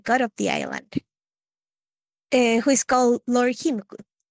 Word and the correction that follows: Kimoko: Was it Himku? Kimoko. Kimoko. Sorry, I can't pronounce names now.